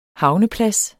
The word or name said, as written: HAVNEPLADS